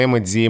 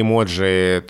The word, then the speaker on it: эмодзи: Там эмодзи, эмодзи, эмоджи, то самое слово, которое все никак не устоится в произношении. – Так, так, так, да, вот как правильно нам говорить. – Да, ну никак неправильно, все еще формируется. И есть просто разные варианты, кто-то один предпочитает, кто-то другой. Это то, что еще не устоялось.